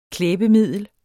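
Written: KLÆBEMIDLER

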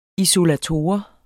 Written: isolator

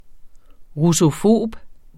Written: russofob

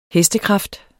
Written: HESTEKRAFT

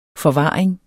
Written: forvaring

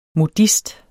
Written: MODIST

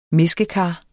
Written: mæskekar

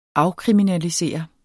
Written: afkriminalisere

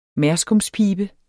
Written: merskumspibe

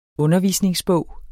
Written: undervisningsbog

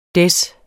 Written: Des

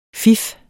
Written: fif

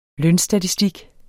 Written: lønstatistik

